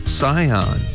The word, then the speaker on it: Sihon: 먼저 시혼을 영어로 이렇게 발음합니다. 잘 듣고 따라해보세요. Sihon, S-I-H-O-N, Sihon 이렇게 발음해주시고 그모스는 C-H-E-M-O-S-H입니다.